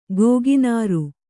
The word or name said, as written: ಗೋಗಿನಾರು